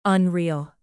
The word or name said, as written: unreal